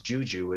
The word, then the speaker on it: Djudju: The DJ producer's name is Juju. It's spelled D-J-U, D-J-U, which is actually Serbian, and it means I.